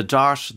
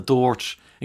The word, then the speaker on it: DART: The way people pronounce the DART train service depends on the stop. It's alternately the DART, the DART, the DORT. In Killiney, it's the DORSH.